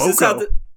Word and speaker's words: Cuoco: Cuoco.